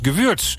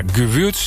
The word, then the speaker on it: Gewürz: Nou, Paul zegt, Bert, het is Gewürz. Dat spreek je uit met een U-klank. Ja, Paul, dank. Ik zei Gewürz, dus ik dacht, dan hou ik dat ook maar vast. Dan zijn we daar consequent in. Maar Gewürz is dus de juiste manier van uitspreken.